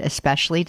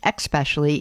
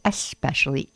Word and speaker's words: especially: I said that the word especially does not have an X in it, so it is not pronounced especially. It is especially, E-S-P-E-C-I-A-L-L-Y, especially.